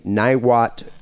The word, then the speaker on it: Niwot: I'm going to say Niwot, Colorado. – Perfect.